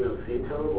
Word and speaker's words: Noceto: My name is Jay Noceto. Last name is spelled N-O-C-E-T-O.